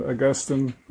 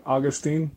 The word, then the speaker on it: Augustine: Would it be accurate to say that, you know, when you compare Tertullian and Augustine or Augustine, can you say it either way? It gets pronounced both ways.